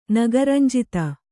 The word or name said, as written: ನಗರಂಜಿತ